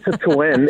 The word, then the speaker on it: Citroën: I love the way you pronounce it, Citroën, exactly.